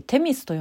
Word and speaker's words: 女神: これは女神と書いてテミスと読む